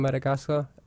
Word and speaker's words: Madagascar: we had a huge revival, you know, a campaign in Madagascar, evangelism.